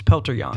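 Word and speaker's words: Petr Yan: Yeah, his or is it Pelter? It's Pelter Yan. – Pelter Yan. Pelter. Yeah, that's right. So we've been saying it wrong too.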